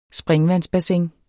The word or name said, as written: springvandsbassin